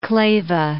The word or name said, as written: Kleve